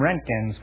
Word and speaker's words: Roentgen: Dr. William Rentgen's contribution to science and medicine is an everyday use. The name is spelled R-O-E-N-T-G-E-N.